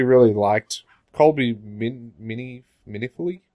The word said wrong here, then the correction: Colby Minifee: I don't know how you say her name. Minifee? Colby Minifee was Robin.